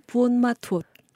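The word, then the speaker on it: Buôn Ma Thuột: 부온마투옷. – 부온마투옷. – 네. 예. 이게 왜 이러냐면 소수민족이 살던 사는 곳이기 때문에 약간 네. 그 언어가 섞여서 네. 이런 발음이 되는데요.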